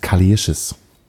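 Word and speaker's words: Kali Uchis: Und ich habe gerade den Namen falsch ausgesprochen. Die Sängerin heißt Kalischis. Kalischis. Kalischis.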